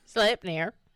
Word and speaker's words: Sleipnir: Sleipnir.